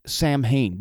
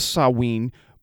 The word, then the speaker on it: Samhain: Now, some people call it Samhain because that's how it is spelled, S-A-M-H-I-N, or H-A-I-N, S-A-M-H-A-I-N. But it's pronounced Samhain.